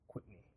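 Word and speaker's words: Kwitney: I believe her last name is Quitney. It's spelled K-W-I-T-N-E. K-W-I-T-N-E-Y. Quitney.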